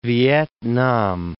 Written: Vietnam

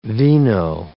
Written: vino

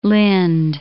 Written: Lind

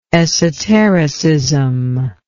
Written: esotericist

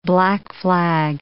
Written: black-flag

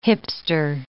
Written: hipster

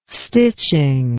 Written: stitching

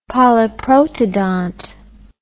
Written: polyprotodont

